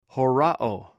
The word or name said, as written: horaw